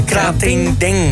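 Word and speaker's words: Krating Daeng: Dat is, ik kan dat niet uitspreken. – Krating Deng. – Deng, ja, iets in die richting.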